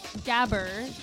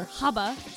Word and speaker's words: gabber: So gabber, as the white people say it, or haba is how you say it in German.